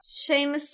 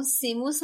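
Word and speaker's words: Seamus: حالا ما با شیمس فینیگان که خب تلفظ اصلی این اسم هست کاری نداریم به همون سیموس هم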